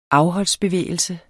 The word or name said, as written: afholdsbevægelse